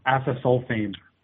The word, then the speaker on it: acesulfame: It's asasulamine, As, acesulfame.